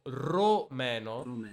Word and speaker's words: romeno: Però in italiano per complicare le cose ci sono due modi di dirlo, cioè rumeno o romeno.